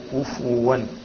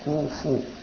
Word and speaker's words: كفوا: بعض الإخوة ينطقون كفوا بتسكين الفاء، والصواب ضمها كفوا، أي نعم تأخذ بل، ولم يكن له كفوا، كفوا مش كفوا، ليس بتسكين الفاء ولكن بضم الايه؟ ضم.